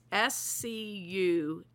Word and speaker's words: Scustie's: I've had a heck of a time pronouncing the name of this place. It's Scustie's. They make their own crust every day.